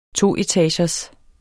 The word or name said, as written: toetagers